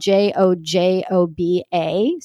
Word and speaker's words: jojoba: I was using jojoba oil, which jojoba is spelled J O J O B A. So you've probably seen it, but it's pronounced jojoba.